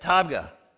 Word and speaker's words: Tabgha: Tabgha.